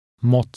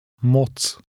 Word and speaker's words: moc: Moc. – The last letter has a TS sound in Czech, as in the end of the word cats. Read number 5 again. – Moc.